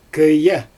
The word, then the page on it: köje: Xyzyl